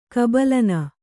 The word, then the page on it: ಕಬಲನ: Kannada